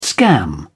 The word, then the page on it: scam: British English